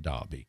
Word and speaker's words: Derby: We pronounce it Derby. We've kind of had this conversation before, before your time, but so I'll repeat it. It's spelled D-E-R-B-Y, but pronounced Derby.